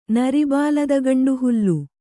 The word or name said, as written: ನರಿಬಾಲದಗಂಡುಹುಲ್ಲು